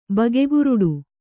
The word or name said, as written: ಬಗೆಗುರುಡು